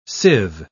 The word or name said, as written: sieve